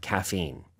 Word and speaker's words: caffeine: they have caffeine. – 맞아요. – But some coffee is decaffeinated, so it was called decaf. – 그쵸. Caffeine이라고 하면 카페인이에요. 발음이 조금 다르죠? Caffeine.